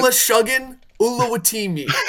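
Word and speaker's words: Olusegun Oluwatimi: Olashuggan Ulawatimi. That's how you say it.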